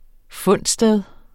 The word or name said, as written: fundsted